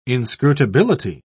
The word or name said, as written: inscrutability